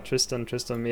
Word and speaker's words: Tristam: I've also been listening to Tristam. Is that how you pronounce it? I'm not quite sure. He used to make like EDM type music back in the day. – Okay. – Tristam, yeah.